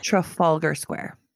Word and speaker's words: Trafalgar Square: T-R-A. – Trafalgar Square.